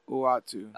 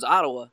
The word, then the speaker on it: Uatu: I was pronouncing it like the wrestling Samoans, Ottawa. – Yeah. – I was just saying Ottawa.